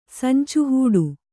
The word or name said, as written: ಸಂಚುಹೂಡು